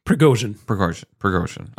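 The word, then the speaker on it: Prigozhin (Пригожин): Even I have like this feeling inside of me, even though I know how terrible this, Prigozhin is. Am I saying that right? – Prigozhin. – Okay.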